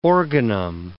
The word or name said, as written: organum